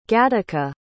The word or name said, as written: gattaca